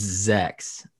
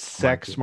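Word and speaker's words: Zechs Marquise: I'm saying, but I'm saying sex marquees. It's Zex. – Zex. No, it's sex marquees. marquees. I mean, no, I, I I think that's totally a better pronunciation. So yeah, sex marquees.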